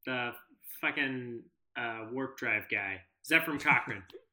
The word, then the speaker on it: Zefram Cochrane: I should have mispronounced it. You know, Zephyr Cochram. That guy.